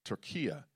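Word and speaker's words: Türkiye: Or as Murat was explaining to me last Sunday, Turkia, because they've went from Turkey. Locally, they pronounce it Turkia, which was an interesting fact.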